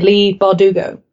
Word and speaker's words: Leigh Bardugo: Speaking of gangs, I recently finished reading Six of Crows and Crooked Kingdom by Leigh Bardugo. Am I saying that right? – Yes.